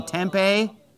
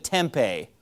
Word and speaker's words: Tempe: tempeh. It's Tempe, Arizona. It's not tempeh.